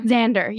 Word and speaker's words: Xander: Xander. Yes. Mm-hmm. It rhymes with my team leader's last name, Glander. I was like, it's not Glander.